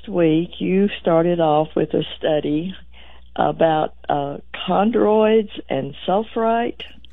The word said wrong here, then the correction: Chondroitin sulfate: Chondroitin sulfate.